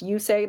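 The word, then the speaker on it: Yusei: And then for people who don't know, obviously his name is pronounced You Say in the Lisa Loeb song. She sings the word like Y-O-U and then S-A-Y. So You Say.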